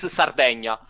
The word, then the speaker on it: Sardegna: In Italy, it's Sardegna. – Sardegna. Okay. – I think the pronounce in English is Sardinia.